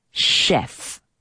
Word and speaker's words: chef: Y ojo con la pronunciación de chef, porque en este caso ch se dice como si fuese sh, the chef, como si estuvieses mandando callar a alguien.